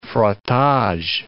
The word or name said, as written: frottage